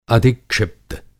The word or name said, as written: अधिक्षिप्त